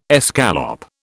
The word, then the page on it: escalope: British English